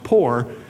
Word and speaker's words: Pur: It comes from the word P-U-R, which I said is pronounced poor,